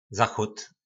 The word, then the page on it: zachod: po našimu